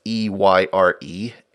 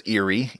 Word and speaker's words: Eyre: Uh, that's E Y R E, Eyre, as in the Circuit of the Royal Justices, and which I always want to pronounce as Erie, even though that is incorrect.